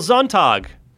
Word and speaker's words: Sonntag: Michael Sonntag. That's the German pronunciation from having taken years of German in high school and college. It means Sunday.